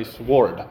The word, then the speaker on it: sword: Katrina says I'm not supposed to pronounce the W. It's there. The W is there, so I say sword.